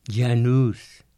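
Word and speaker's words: Jan Hus: Janus, que así se pronuncia Juan de Hus,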